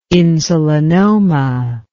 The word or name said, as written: insulinoma